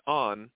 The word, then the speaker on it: Ahn: My name is spelled A-H-N, but it is pronounced just on, like a light switch.